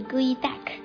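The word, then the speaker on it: geoduck: 象拔蚌发音是 Gui Dak，是一种大型的蛤蜊，寿命极长。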